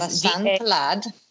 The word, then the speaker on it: Vasant Lad: The author, Dr. Vasant Lad, his name is Vasant Lad. – Perfect pronunciation.